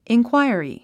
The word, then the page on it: inquiry: inkwǽiəri; インクワイアリィ